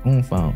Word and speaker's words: Unfoonk: U-N-F-O-O-N-K. Oomphunk.